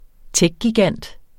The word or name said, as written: techgigant